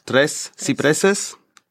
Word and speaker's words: Tres Cipreses: Und das spanische Label, ich denke mal, man müsste es auf Spanisch aussprechen, Drei Cipreses, also wie Zypressen, hat diese Platte. – Tres Cipreses. Merci.